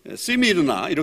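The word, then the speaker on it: Smyrna: 이것은 이유가 있는데 이 소모나라고 하는 이름과 아주 밀접한 연관이 있습니다. 소모나라고 하는 이름의 뜻이 무슨 의미냐면 이게 시미르나 이렇게 이제 발음도 되는데 이거는 향료를 얘기합니다.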